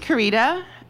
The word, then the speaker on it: Kerrita: Hi, my name is Karita, and I am a mother's girl. My name is spelled K-E-R-R-I-T-A, and this will be important later.